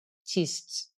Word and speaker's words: schist: And then you have a little bit, but less soils that came from the metamorphic rock schists.